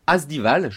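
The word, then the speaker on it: Asdiwal: Vous êtes chargé de recherche au CNRS, vous êtes membre fondateur de la revue d'anthropologie et d'histoire des religions Asdival, je le prononce bien ? – C'est juste.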